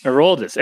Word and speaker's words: Aroldis: You know, it's like Chapman who's throwing 100, or Alondis Chapman. And then I always say his first name wrong. How do you say it? – Aroldis.